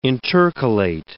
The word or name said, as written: intercalate